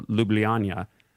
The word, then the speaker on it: Ljubljana: Ljubljana, which I had to learn how to pronounce because it's spelled L-J-U-B-L-J-A-N-A.